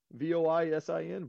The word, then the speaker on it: Voisin: Uh, Devin, I don't even know how to say his last name. Voisin, V O I S I N, Voisin. – I think so.